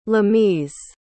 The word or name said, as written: lameez